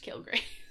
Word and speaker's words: Kilgrave: I would be, like, nerfed Kilgrave.